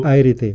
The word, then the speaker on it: Planchart: Planchart. – Como plancha, como plancha, y termina art. – Art. – Como arte, Planchart.